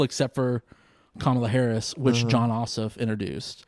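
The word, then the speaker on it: Jon Ossoff: And like, I was like, bringing to the stage, John Ossoff.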